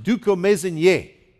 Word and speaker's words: dukomezenye: It's a very rare word in Kinyarwanda, but it's pronounced dukomezenye. Now, you need to say it that way. You put the emphasis on the last syllable, okay?